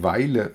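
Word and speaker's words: Vejle: Welje wird Weile ausgesprochen.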